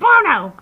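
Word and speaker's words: Buono: and I couldn't say his name right, so it was like Angelo Banana Hammock and Angelo Baloney and all these different names because I couldn't pronounce it correctly ever. And Kelly would get so frustrated. She's like, "It's Bono," and I'm like, "I know."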